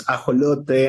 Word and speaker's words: ajolote: In Spanish, it's, you know, I think it sounds nice, right? It's ajolote, and it's an endemic amphibious animal from Mexico.